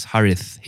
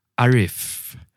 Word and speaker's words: Harith: They don't say Harith. They say Arif. Why would you? My own mom and dad, they call me Harif.